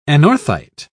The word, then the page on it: anorthite: British English